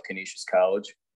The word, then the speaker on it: Canisius College: Oh, by the way, you did nail the pronunciation of Canisius College. you nailed it.